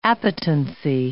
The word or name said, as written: appetency